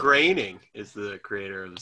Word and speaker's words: Groening: It's pronounced Groening.